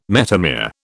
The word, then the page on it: metamere: British English